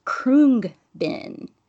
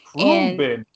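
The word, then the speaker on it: Khruangbin: So the name of the band is Krungbin. – Krungbin.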